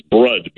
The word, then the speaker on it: Brud: Hey, Brood. Is that how you say your name? Brood? In Michigan. – Hey, Howard. No, I'm sorry. It's Brud. B-R-U-D.